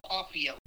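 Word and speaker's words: Cauffiel: How would you pronounce his last name? – Yeah, I think you're saying it right. It's Lowell Caufiel.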